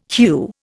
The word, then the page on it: HEW: Indian